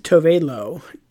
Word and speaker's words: Tove Lo: but it's I think you pronounce it Tove Lo, T-O-V-E space L-O, and the song is called Habits.